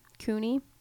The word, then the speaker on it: CUNY: Rick here sent me this article about, it's called City High School Grads at CUNY, CUNY is how you say it, needing remedial education.